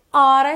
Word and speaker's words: ought: Deixei até uma dica aí de pronúncia. Pode pensar em hora, como a gente fala que horas são. I ought to study more or I ought to study more, ok?